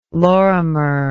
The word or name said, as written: lorimer